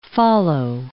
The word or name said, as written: follow